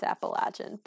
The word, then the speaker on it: Appalachian: You can say it both ways, but if you're saying it right, it's Appalachian.